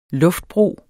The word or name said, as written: luftbro